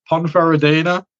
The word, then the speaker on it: Ponferradina: And it's Ibiza against Ponferradina. I hope I'm saying that right. – Oh, you are. Yeah, that's good pronunciation.